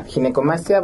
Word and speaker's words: ginecomastia: Pues muchas gracias, y sí, definitivamente creo que es una, para empezar la palabra, le decía, ¿cómo se pronuncia? Ginecomastia. – Sí, exacto, es ginecomastia.